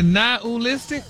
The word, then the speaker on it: nihilistic: But you actually pronounced it correctly the last time you said it. – Really? Nihilistic? – Wow. – Stop putting the ooh in there. – Nihilistic? – There you go.